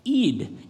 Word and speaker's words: Eid: And she said, oh, Eid. It's like eat because we eat.